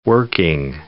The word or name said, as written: working